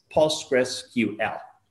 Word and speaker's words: PostgreSQL: Gress, it goes after that. Uh, the E sounds like invest, so it's not like post grass. So some people slightly pronounce it like that way. That's probably okay, as long as then you go with the Q and L. They are pronounced like if they were individual letters. So it's not PostgreSQL, it's PostgreSQL, right? Easy.